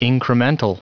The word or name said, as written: incremental